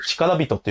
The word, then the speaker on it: 力士: 力士と書いて力人って読むんですけど、ラジオで伝わりにくいんで、力士と読みますね。